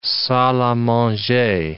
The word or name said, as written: salle a manger